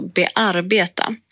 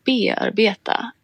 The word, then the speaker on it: bearbeta: Jo, det var så att jag hade en gång en tyskspråkig föreläsare på universitetet som konsekvent sa ordet bearbeta med betoning på andra stavelsen, alltså bearbeta. Mm. Vilket ju är samma betoningsmönster som tyskan har på det ordet, alltså bearbeiten. – Ja. – Och det här fick mig att fundera på varför vi på svenska säger bearbeta med betoning på första stavelsen.